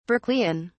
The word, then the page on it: berkeleian: British English